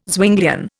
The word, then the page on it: Zwinglian: Indian